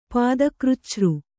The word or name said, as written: ಪಾದಕೃಚ್ಛø